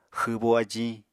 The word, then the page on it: xwbo ajiñ: Apatani